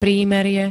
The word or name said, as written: prímerie